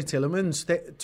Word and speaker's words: Tielemans: When you think about Youri Tielemans, 24 years old, performing at Leicester this season, there's a player that...